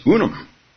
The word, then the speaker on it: unum: E pluribus unum. I don't know, I didn't do Latin, so maybe it's unum. What is it? How do you pronounce it? Unum. Okay, e pluribus unum.